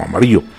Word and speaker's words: Amarillo: Aprovechamos este instante para poder enviar un saludo muy especial para quienes viajan por allá en Amarillo, Texas. Amarillo, Texas, así es, o podemos pronunciarlo como Amarillo.